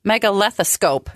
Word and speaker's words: megalethoscope: It's called a megalethoscope.